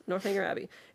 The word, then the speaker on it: Northanger Abbey: So Northanger, how do you say that? – Northanger Abbey? – Yes, Northanger Abbey.